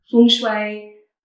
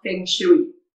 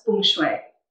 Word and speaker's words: feng shui: So, it can be pronounced feng shui, uh, feng shui, feng shui, just not feng shui.